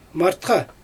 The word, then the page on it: martxa: Xyzyl